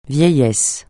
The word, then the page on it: vieillesse: francais